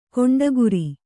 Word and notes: ಗೂಂಡಾಗಿರಿ: kannada

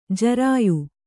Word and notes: ಜರಾಯು: jarāyu

